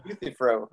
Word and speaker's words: Euthyphro: Euthyphro. I think accent on the first syllable. I believe Euthyphro.